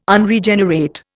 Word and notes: Unregenerate: Indian

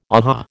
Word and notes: aha: American English

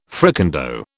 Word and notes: fricandeau: British English

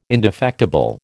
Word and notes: indefectible: American English

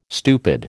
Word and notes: stupid: American English

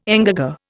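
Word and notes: engagé: Indian

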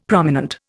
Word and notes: prominent: Indian

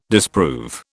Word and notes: disprove: British English

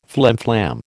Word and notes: flimflam: American English

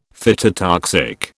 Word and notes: phytotoxic: British English

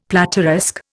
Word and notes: plateresque: Indian

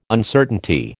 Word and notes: Uncertainty: American English

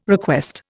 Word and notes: request: Indian

